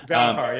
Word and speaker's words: valonqar: Valonqar, yeah.